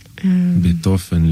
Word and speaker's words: Beethoven: Beethoven, lo pronuncia en alemán.